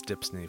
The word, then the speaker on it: DHPSNY: Just to recap, DHPSNY, which we pronounce Dipsney for short, stands for Documentary Heritage and Preservation Services for New York.